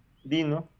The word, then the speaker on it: Deno: Y Dino, y así es como se llama la nueva competencia de Node, que es muy similar, Node, Dino, Node, Deno. – Invirtió las sílabas nada más.